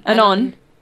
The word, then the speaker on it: anon: Straight add-on. Add-on? Is that how you say it? – Add-on. – Add-on. I don't know. Anyway. – Anon. – Yeah, anonymous. Anonymous.